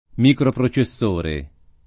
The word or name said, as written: microprocessore